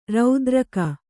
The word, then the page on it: ರತಿರಾಗಿ: rati rāgi